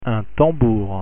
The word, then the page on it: tambour: French